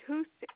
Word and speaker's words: Huth: My name's Trinity Huth, HUTH.